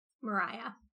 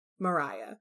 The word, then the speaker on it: Maria: The English pronunciation is Mariah, but that's how they spelled it. – Okay, well, it's spelled Maria. – Yes, but in England, they pronounce it Mariah.